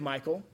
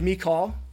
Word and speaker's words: Michal: So the way of Michal, if you want me to pronounce it in maybe a slightly more feminine way, and the way of the maidens or the servants of the female servants.